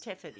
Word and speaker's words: Thetford: He's 47 and he's from, I think you'd pronounce it, Tetford in Norfolk, Schmidt, T-H-E-T-F-O-R-D. – Tetford, I guess. – Tetford, yeah.